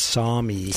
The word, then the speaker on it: Sami: Yeah, it's Sami.